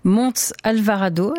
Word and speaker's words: Montes Alvarado: Mardi dernier, le pape a nommé une femme à la tête de la communication du Vatican, Montes Alvarado. Est-ce que ça se prononce comme ça, oui? – Oui, oui.